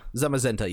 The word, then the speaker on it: Zamazenta: Z-A-C-I-A-N? – Zacian? – Zamazenta. – Zamazenta, yeah.